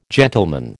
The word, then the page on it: gentleman: American English